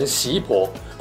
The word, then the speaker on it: 湿婆: 但大家要知道，湿这个字也有习的读音，如果念成习婆，会更接近梵文的读音。